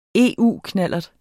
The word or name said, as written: EU-knallert